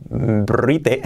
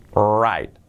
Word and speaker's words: write: Cuidado que la pronunciación es right, right, La W como si no sonara, como si no existiera, la R que hemos dicho antes, y esa I es una I, I, I, right, right, right.